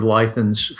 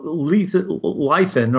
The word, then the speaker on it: Leithen: So Lythan, or however his name is pronounced, L-E-I-T-H-E-N, Lythan is introduced.